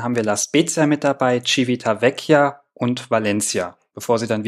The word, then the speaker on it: Civitavecchia: Das Schiff fährt immer samstags ab Genua mit Stops in Marseille, Syrakus, Tarent und Civitavecchia. – Ja, liebe Zuhörer, Zuhörerinnen, das war das erste Mal, dass Civitavecchia richtig ausgesprochen hat.